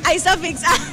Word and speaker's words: Isofix: Creo que sí lo dije bien. ¿Isofix? ¿O Isofix? Isofix, la Sofi dice.